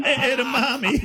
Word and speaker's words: edamame: Edamame.